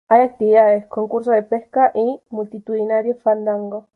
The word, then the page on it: multitudinario: spanisch